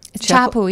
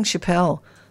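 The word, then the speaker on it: Chappell: How do you say it? Is it Chappelle? – Chappelle, yeah. – Ah, I was saying Chappelle, the girl that got busted in Bali.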